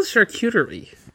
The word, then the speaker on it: charcuterie: Did I say that wrong? – Yeah, you did a different word in there. – Hey, Google, how do you say chartreuserie? Hold on. Is it chartreuserie board? Charcuterie? All right. You win this one.